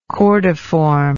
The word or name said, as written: cordiform